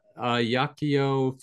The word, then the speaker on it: Ajaccio: Tristan lives in Ajaccio, France.